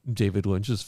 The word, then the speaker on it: Lynch: Is it David Lynch? Did I say that wrong? – No, it's David Lynch.